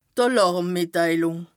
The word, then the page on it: Àlàrmmìtteilùng: Bas Rhin